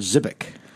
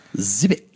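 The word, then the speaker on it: Żywiec: And the C can be either a C or a CH. – Okay. – So I heard Zivic. – Zivic. – That sounds pretty close. It for the listeners out there, it's spelled Z-Y-W-I-E-C.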